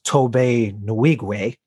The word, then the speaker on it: Tobe Nwigwe: This record that Hill got me just arrived from I'm going to try to get his name right as best I can, which I believe is pronounced Tobe Nwigwe.